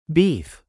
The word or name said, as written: beef